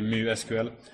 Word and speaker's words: MySQL: And no one ever says Mu SQL, but the Swedish correct pronunciation would be Mu SQL.